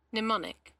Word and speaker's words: Mnemonic: Mnemonic.